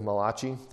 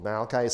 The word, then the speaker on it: Malachi: So everything from Genesis 1:1 all the way to the end of the book of Malachi, using the Italian pronunciation, the book of Malachi, the book of Malachi.